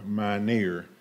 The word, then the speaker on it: Mynhier: My name is Tyrone Mynhier. That's M-Y-N-H-I-E-R, and the H is silent. Mynhier, M-Y-N-H-I-E-R.